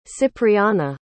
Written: cypriana

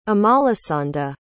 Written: amalasanda

